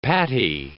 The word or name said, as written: pattie